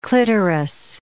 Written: clitoris

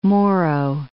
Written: Moro Gulf

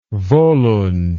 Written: volund

